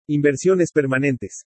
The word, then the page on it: INVERSIONES PERMANENTES: Spanish